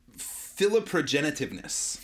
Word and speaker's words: philoprogenitiveness: Philoprogenitiveness. – Philoprogenitiveness, says we, is strong in semi-rural communities.